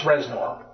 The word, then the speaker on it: Reznor: Reznor, like Trent? – Yeah. Except it's pronounced Reznor. R-E-Z-N-O-R.